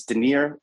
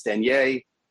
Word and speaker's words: Denier: It's Denier in the U.S. When I go to Europe, it's Denier. It changes.